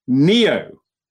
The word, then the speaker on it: Niio: It's called Neo, which is spelt, of course, it's not spelt normally. It's spelt N-I-I-O.